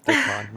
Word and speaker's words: Dickon: A friend of Dickens, like, yeah Dickon, sorry, I was saying his name wrong. It's Dickon, not Dickens.